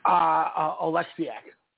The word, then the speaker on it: Oleksiak: I think I said that right? – Oleksiak.